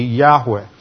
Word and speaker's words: Yahweh: And this was probably a short E, Yahweh.